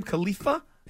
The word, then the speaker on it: Khalifa: Khalifa? – Yes.